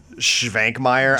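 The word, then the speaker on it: Švankmajer: Also, but yeah, before we go, check out Jan Svankmajer's And phonetically, it looks like John Svankmajer, I guess.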